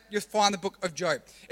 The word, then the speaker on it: Job: And it's spelled J-O-B. So, if you look, find the book of Job, it's actually pronounced Job.